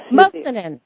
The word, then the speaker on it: Mossanen: Did I say that right this time? – Yes. – Mossanen. – Yes. – It's okay. – Levy. Just Levy. It's Dora Levy Mossanen.